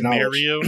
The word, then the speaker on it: Mario: He he and Mario Ellie get credit for bringing like championship mentality. It's pronounced Mario.